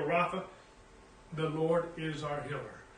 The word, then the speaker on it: Rapha: Jehovah Rapha is how it's pronounced in Hebrew.